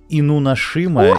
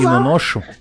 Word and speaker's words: Инунашима: какое правильное ударение. Э, инунашима О, ты или инуношу. Нет, самое первое ты правильно сказал. – Правильно? Инунашима. Инунашима? Да.